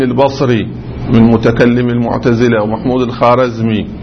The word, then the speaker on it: الخوارزمي: هذه الواو لا تنطق في اللغة الفارسية، يقولون الخاجة ما يقول الخواجة، ما يقول الخوارزمي، هي الخارزمي، مثل خاهر برادر، الواو بعد الألف لا تنطق، بس العرب يقول الخوارزمي، هذا اشتباه.